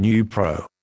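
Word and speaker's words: Neupro: This is the consumer medicine information for Nupro, pronounced Nupro.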